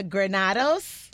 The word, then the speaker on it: Granados: I have Licensing Assistant Director, Sonia Granados.